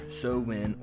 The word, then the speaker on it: Samhain: The Celtic festival of Samhain is practiced every year, and it is pronounced either Samhain or Samhain, and it is spelled S-A-M-H-A-I-N, and is the first of the great fire festivals.